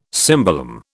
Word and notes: cimbalom: British English

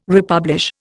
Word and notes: republish: Indian

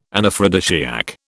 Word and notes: Anaphrodisiac: British English

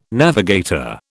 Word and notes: navigator: British English

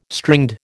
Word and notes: stringed: American English

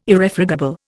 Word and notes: Irrefragable: Indian

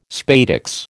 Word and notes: spadix: American English

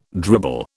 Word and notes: dribble: British English

